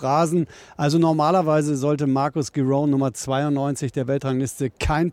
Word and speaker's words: Giron: Ja, er spricht sich Giron aus tatsächlich,